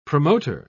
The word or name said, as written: promoter